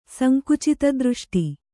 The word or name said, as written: ಸಂಕುಚಿತದೃಷ್ಟಿ